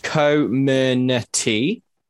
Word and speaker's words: Comirnaty: okay, so the vaccine is pronounced Comirnaty, and it's a mashup of words, but so co stands for coronavirus.